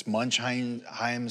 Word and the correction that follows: Munchausen: syndrome or something. What is it? Munchausen.